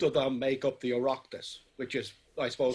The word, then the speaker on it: Oireachtas: How do you say that word? Oireachtas? – Oireachtas. – Oireachtas, okay, close.